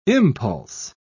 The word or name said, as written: impulse